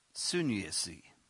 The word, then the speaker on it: sunesis: Let me spell this for you before I pronounce it. S-U-N. That was easy, son. S-U-N-E-S-I-S. Sunesis. S-U-N-E-S-I-S.